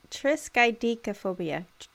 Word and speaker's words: triskaidekaphobia: The word is triskaidekaphobia. Am I saying that correctly? Triskaidekaphobia. – Triskaidekaphobia. Triskaidekaphobia. – Triskaidekaphobia. – Yeah.